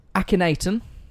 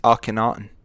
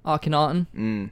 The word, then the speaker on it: Akhenaten: So his parents, his father was Akhenaten. – It's Akhenaten. – Akhenaten.